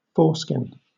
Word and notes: foreskin: British English

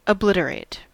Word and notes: obliterate: American English

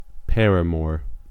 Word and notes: paramour: American English

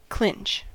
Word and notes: clinch: American English

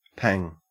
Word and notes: pang: Australian English

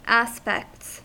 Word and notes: aspects: American English

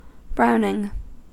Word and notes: browning: American English

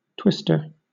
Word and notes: twister: British English

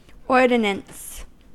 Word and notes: ordinance: American English

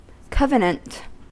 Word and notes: covenant: American English

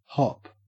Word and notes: hop: Australian English

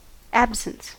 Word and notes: absence: American English

